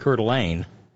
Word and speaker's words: Coeur d'Alene: The locals pronounce it Coeur d'Alene, and it probably is. I mean, at least in terms of being near the original French.